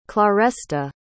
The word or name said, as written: claresta